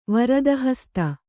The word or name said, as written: ವರದಹಸ್ತ